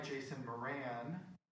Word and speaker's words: Jason Moran: The music is by, make sure I get his name right, Jason Moran. Am I correct? Yeah.